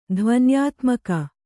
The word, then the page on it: ಧ್ವನ್ಯಾತ್ಮಕ: dhvayātmaka